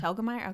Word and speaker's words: Telgemeier: Telgemeier, okay.